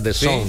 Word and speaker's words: DAZN: DAZN. – Sí. – DAZN. DAZN. – Sí. – Que es como la zona, ¿verdad? De Zone, sí. Pero escrito como se pronuncia.